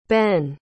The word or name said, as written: benn